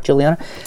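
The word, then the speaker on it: Guiliana: I'm going to pronounce it that way. Mark Juliana.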